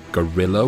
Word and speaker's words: guerrilla: We call this sort of fighting guerrilla warfare. – Guerrilla? Like a giant black ape? – It's pronounced like that, but it's spelt differently.